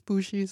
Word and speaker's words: bugi: Og så når det udtales Bugi, så er det bare B-U-G-I.